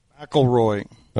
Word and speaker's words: McIlroy: McIlroy.